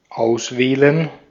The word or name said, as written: kiezen